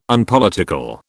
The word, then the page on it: Unpolitical: British English